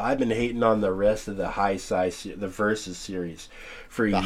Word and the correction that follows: Heisei: It's Heisei.